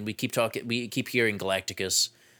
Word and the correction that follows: Galactus: Wait, I said that right? – What? Galactus? – Galactus.